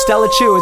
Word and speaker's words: Stellachuu: Because it's not Chew. Just Stella Chu, it's pronounced Stella Chu.